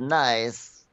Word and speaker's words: Nice: I only pronounce the word nice like niece.